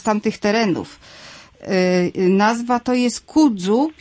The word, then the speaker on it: kudzu: Pisze się K-U-D-Z-U. – K-U-D-Z-U. – D jak Danuta. – Tak. – Takie polskie dzy. Polskie dzy, kudzu.